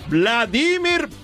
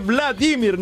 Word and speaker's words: Vladimir: Berlusconi si tenga Bush, perché lui c'ha Vladimir Putin. E mi raccomando, la pronuncia si dice Vladimir, non Vladimir. Vladimir Putin.